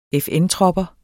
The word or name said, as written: FN-tropper